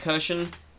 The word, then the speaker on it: Kirshen: K I R S H E N is the correct spelling, but get even vaguely within the ballpark and it's.